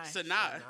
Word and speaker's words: Sanaa: Is it Shayna or Sanaa? – Sanaa. – Okay, I've been saying Shayna.